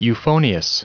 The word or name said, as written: euphonious